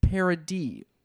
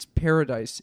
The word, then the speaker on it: Paradis: But two words, Ella, E-L-L-A, and Paradis. Which is a French pronunciation, but really it's paradise in French. Oh. So it has an S at the end. P-A-R-A-D-I-S. Paradis.